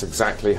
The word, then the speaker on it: exactly: That's exactly how I feel. – Esta es una expresión para mostrar que estás de acuerdo. En la palabra exactly, vamos a subir la entonación en la segunda sílaba.